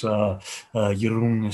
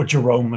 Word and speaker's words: Giron: Giron is probably the best way. And then Rune, like Rooney, I guess. – Okay. – Or Jerome is also fine.